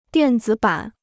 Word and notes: 电子版: diàn zǐ bǎn

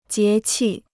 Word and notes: 节气: jié qi

